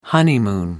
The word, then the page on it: honeymoon: American English